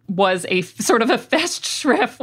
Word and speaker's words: Festschrift: Angela and Stephen mentioned this thing that I'd never heard before called Festschrift. Is that how it's pronounced? – Yeah, well, it's German, so I'm sure I'm butchering it. But yeah, Festschrift.